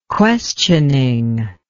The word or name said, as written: questioning